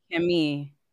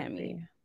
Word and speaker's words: Chemie: Chemie.